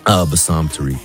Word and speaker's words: Bassam Tariq: Bassam Tariq.